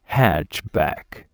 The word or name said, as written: hatchback